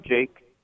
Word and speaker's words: Jake: I'm Jake. – Yes. Jake? Jake, yes, I can, Jake. Forgive me for adding a final syllable to your name there.